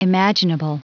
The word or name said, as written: imaginable